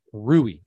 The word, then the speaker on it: Rui: How do you say Rui? – Rui. I keep wanting to call him Rudy.